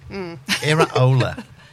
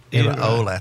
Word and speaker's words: Iraola: And this is how you pronounce it. – Iraola. – What? – Iraola.